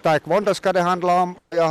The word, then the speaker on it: Taekwondo: Ni representerar Tenala-föreningen Tornados ITF Taekwondo.